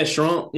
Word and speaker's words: Charente: It's that the last syllable, the on, instead of yep. Yeah, Charente. Yeah, you don't say the yeah. – Yeah. – Yeah, just don't say the S at the end.